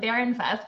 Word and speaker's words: Barenfuss: I'm here with Stacy, and I would pronounce it Barenfuss because I speak German. But how do you pronounce your name, Stacy? – Ryan, so I pronounce it Barenfuss, but I probably should take lessons from you because it is in fact German.